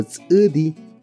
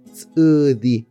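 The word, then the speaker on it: ts'ʉdi: Eh, para escribir Sudi tenemos la consonante TS, glotal U, que es una U con la rayita abajo. Y tenemos la D y la I. Sudi.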